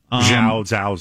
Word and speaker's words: Chloe Zhao: Up for Best Director, Chloe Zhao. Is that the way you say her name? – Yes. Zhao, something like that.